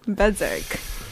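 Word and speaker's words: Berzerk: It's by this Norwegian group called Apoptygma. Berserk. – Berserk, yeah. – Perfectly pronounced it.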